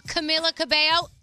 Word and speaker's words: Camila Cabello: Camila Cabello, do I say her name right? – Yeah.